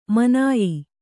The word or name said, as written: ಮಾನಾಯ